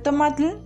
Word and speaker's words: tomatl: Tomate es otro ejemplo. Se pronuncia tomatl y significa agua gorda.